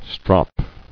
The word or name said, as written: Stropped